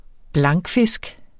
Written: blankfisk

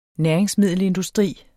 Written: næringsmiddelindustri

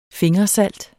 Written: fingersalt

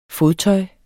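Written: FODTØJS

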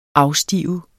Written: afstive

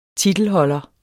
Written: titelholder

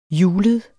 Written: hjulet

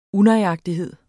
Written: unøjagtighed

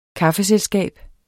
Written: kaffeselskab

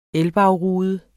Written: elbagrude

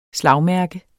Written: slagmærke

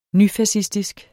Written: nyfascistisk

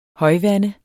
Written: højvande

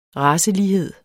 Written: racelighed

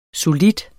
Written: solid

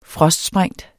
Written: frostsprængt

